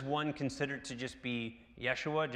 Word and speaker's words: Jeshua: Then there's this high priest, and they pronounce him, they spell it J-E-S-H-U-A. It's pronounced Yeshua, which is Joshua.